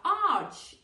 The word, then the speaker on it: arch: In arch, it is the other way. Pero en arco es de la otra manera, arch, es decir, se pronuncia CH como en church. No arc, como en architect.